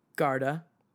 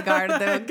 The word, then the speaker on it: Garda: can you say it like the locals do? – Garda. – They say Garda.